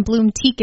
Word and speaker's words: Bloomtique: Bloom Teak is spelled B-L-O-O-M-T-I-Q-U-E Farm.